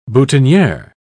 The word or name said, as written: boutonniere